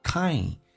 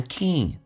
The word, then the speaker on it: kind: One, 有一个前鼻音 ,one kind。 I 这个发音首先要饱满一点，先打开口腔啊，再发 e kind，很多人会发成 king，那是有问题的。